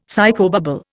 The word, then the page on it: psychobabble: Indian